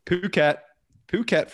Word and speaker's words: Phuket: Now it's Phuket, for anyone that is not aware, but often mispronounced.